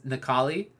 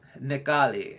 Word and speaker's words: Necalli: His name is Nekali. – That's a hipster-ass hipster name. – Yeah. It is spelled N-E-C-A-L-L-I. – Wow. Okay. Wow. – Nekali.